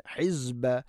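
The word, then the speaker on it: ഹിസ്ബ (حزب): ഇന്ന നൂൻ ഷദ്, ഹിസ്ബ, ഹിസ്ബ അല്ല, സീനല്ല, സായാണ്.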